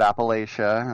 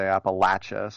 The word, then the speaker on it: Appalachia: And being from California, I always said Appalachia until I was corrected by the people who live there. They say Appalachia, so I'm trying to say it right for them.